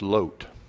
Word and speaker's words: Lot: Balak was king over the Moabites, a people descended from Abraham's nephew Lot, or the correct Hebrew pronunciation of that is Lot.